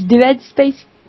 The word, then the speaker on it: Dead Space: Dans Dead, il y a écrit D-E-A-D. Tu dis Dead Space. Ça marche mieux.